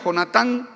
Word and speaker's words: Jonatán: El rey Saúl fue el primer rey de Israel y uno de sus hijos era Jonatán, como se pronuncia, Jonatán.